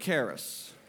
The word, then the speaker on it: charis: I said a couple of weeks ago that the Greek word for grace is charis. Everybody say that word, charis. I want to spell it for you. C-H-A-R-I-S. C-H-A-R-I-S.